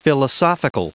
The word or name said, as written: philosophical